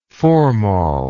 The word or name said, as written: formol